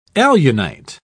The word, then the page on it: alunite: British English